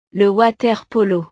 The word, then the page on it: le water polo: frances